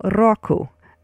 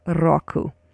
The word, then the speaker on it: року: And so roll your R, and it sounds sort of like rock, so, and then add an U at the end, so року.